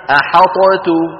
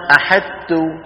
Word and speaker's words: أحطت: ولو أدغمنا إدغاما كاملا لقلنا أحدت. أحدت أحدت ما تقال. شو أحدت؟ صار مثل نطق الأطفال يعني، ما هي يا لطيف. فأحدت ما أحد يقول أحدت ولا أحد يقول أحاطت. كانت العرب تدغم الطاء في التاء في مثل هذا ولكن تبقي صفة الإطباق ظاهرة.